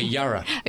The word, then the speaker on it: Yarra: Then we will be back, we will fly to, we're speaking on the 8th of April on Sunday at Jarra. Is that Jarra? Jarra Valley. – Spelled with a Y or a J? – A Y. – Okay, Yarra.